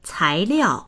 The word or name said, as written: cai2liao4